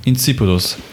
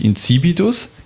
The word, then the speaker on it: Insipidus: Insipidus. Ja, die Betonung ist wichtig. Insipidus. Insipidus.